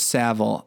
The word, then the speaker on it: Savile: I can't even pronounce Savile. English. – I think it's Savile.